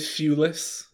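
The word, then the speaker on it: Thewlis: David Shulis? Is that how you say his name? – Yeah. Oh, he'd be a good robot, actually.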